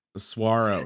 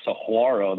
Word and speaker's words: Saguaro: There is a there is a Saguaro the way you're saying it. That's actually in Tucson, and it's uh there's an H in there. So ours is with a G, and the G is silent but kind of pronounced like a W.